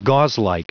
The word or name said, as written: gauzelike